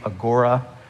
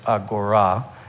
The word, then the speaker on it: agora: We say agora, but the proper pronunciation is agora.